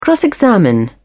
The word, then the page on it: cross examine: British English